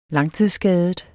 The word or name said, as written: langtidsskadet